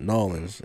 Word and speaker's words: New Orleans: She watched New Orleans. – That. – Nolans. You said it wrong. – No, is it Nolans? New Orleans? – Nolans. – Oh, it's just Nolans. – One word, Nolans.